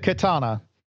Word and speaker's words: Khetanna: It is, in fact, the katana. – Oh, yay. – But it is not like the Japanese katana sword. – No, it is spelled K-H-E-T-A-N-N-A.